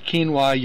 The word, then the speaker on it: quinoa: And you have spelled quinoa your own way. – Right, yes, K-E-E-N-W-A-H, so phonetically.